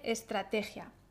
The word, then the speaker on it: estrategia: vamos a ver cuántas sílabas tiene estrategia. Estrategia. Estrategia. Tiene cuatro sílabas. Es tra te gia. Estrategia. La sílaba fuerte es la T.